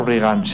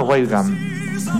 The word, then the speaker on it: Reagan: Y otra cosa, no se pronuncia Reagan, se pronuncia Weigand.